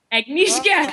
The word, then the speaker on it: Agnieszka: Agnieszka, that's right.